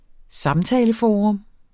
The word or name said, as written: samtaleforum